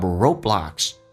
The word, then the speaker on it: roadblocks: 因此 I don't put up roadblocks to what you do，因此我不会在你人生当中啊建立一些路障 roadblocks， road 的 d 不发音，阻塞一下，两个辅音在一起 d 阻塞 roadblocks。